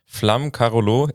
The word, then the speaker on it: Flamme Carolo: Ich habe Finn davor schon fragen müssen, wie spricht man denn jetzt aus? Weil ich war mir nicht ganz sicher, aber jetzt wissen wir es, ja. Flamme Carolo, ich merke es mir, ich versuche es auch richtig zu machen.